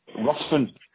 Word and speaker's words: Ruthven: Ruthven. R-U, so it's spelled, well, R-U-T-H. – R-U-T-H. – V-E-N.